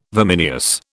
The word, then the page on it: vimineous: British English